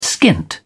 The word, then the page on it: skint: British English